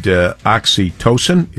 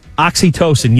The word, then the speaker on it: oxytocin: You got to hug for at least 20 seconds, and you get all of the good oxytocin. Is that how he said to pronounce it? – Oxytocin, yes. – Yeah, oxytocin. Okay.